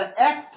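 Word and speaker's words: ek (ἐκ): Even if you can't read Greek, you probably can pronounce that as ek because it looks like an E and a K, doesn't it?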